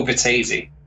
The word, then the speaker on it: Vettese: So like, here's the kicker, not even I know really know how to pronounce my own name properly, because I don't pronounce my THs and my Vs properly, but it's Nico Vettese.